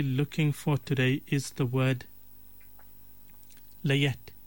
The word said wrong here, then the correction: layette: It's the word layette. The layette is, it's spelled L-A-Y-E-T, and then there's a T-E at the end, which is like almost silent. You don't really pronounce it. It's just pronounced as layette.